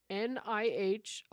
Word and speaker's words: Nihonshu: How do you say sake in Japanese? – Oh, you are trying to say... – Nohushu. – Oh! – Nihonshu. – Nihonshu. – Nihonshu. – Nihonshu, yes.